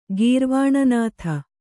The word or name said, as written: ಗೀರ್ವಾಣನಾಥ